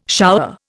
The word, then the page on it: shower: Indian